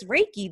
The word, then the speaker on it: Reiki: listening that maybe they've heard the word, they've probably seen the word, and just now like, oh, that's how you say it is Reiki. That's how you say that word.